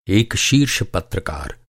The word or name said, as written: पत्रकार